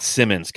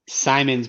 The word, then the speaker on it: Simons: Simons winning the slam dunk contest. So, and who knows the pronunciation of his last name? – Yes, it's not Simmons.